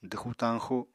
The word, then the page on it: dwxu-tañxu: apatani